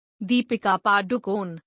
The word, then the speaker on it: Deepika Padukone: Deepika Padukone, pronounced Deepika Padukone, born the 5th of January 1986, is an Indian film actress.